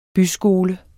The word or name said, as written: byskole